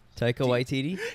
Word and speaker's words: Taika Waititi: So, how does it feel to have, like, is it pretty cool to see, like, is Taiki Waititi? I want to say it right. Taiki Waititi? – Taiki Waititi. – Taika Waititi? – Taika Waititi.